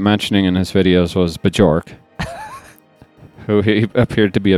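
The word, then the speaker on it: Björk: You have to pronounce the J in there, by the way. – Oh, okay. – It's not Bjork. It's Bjork.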